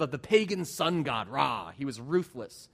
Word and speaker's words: Ra: Think about the first two letters of his name, Ramses, R-A. How do you pronounce that?